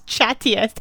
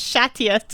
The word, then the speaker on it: chattiest: You know that in English that's chattiest, and you've said it's shattiest to try and make it sound like French cats or something.